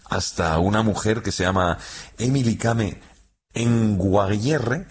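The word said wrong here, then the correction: Kngwarreye: lo he dicho mal. – Emily Kame. – Con Guarreye. – Emily Kame con Guarreye, sí.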